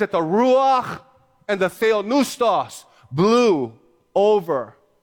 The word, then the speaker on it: ruach: The word in the Hebrew is ruach. Ruach. You got to have the CH. You got to go to... That's how you say it.